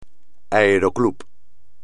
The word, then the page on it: aeroclub: espagnol